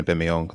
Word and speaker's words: Aubameyang: Shout out to Adrien Aubameyang. That's how you pronounce his last name.